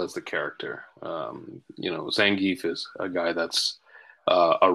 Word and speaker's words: Zangief: Is that Zangief? Am I saying that right? – You got it. Zangief.